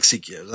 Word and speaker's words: Seiko: I like Seikos. I've got two Seikos. – I think you mean Seiko.